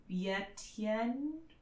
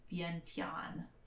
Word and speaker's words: Vientiane: Or Yetien? It was clear that he knew it, but didn't quite pronounce it closely enough to correct. It's Vientiane.